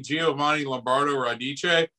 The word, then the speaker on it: Giovanni Lombardo Radice: So a little known fact, when saying Giovanni Lombardo Radice, his whole last name is not Radice, it's Lamberto Radice.